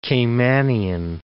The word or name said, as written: Caymanian